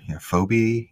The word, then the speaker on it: Phoebe: And you walk in, and it's Elite Four member Phoebe.